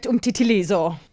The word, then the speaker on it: umtitilizo: I really love to shop at Jam because you always like get a great umtitilizo.